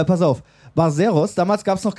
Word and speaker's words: Barzeros: Yes, Barzeros.